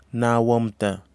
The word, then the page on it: nawamta: kwamera